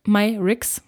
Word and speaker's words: Myricks: Und zwar das Colorfix von Danessa Myris, Myrix, so spricht man das aus.